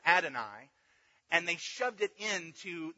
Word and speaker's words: Adonai: When they came to this, they would pronounce it Adonai.